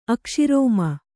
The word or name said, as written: ಅಕ್ಷಿರೋಮ